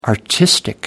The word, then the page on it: artistic: American English